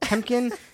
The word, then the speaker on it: Temkin: So my name is it's spelled Temkin, T-E-M as in monkey, K-I-N as in nuts.